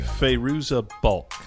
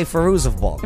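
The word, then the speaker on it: Fairuza Balk: Feruza Balk. – There you go. Okay, Feruza Balk.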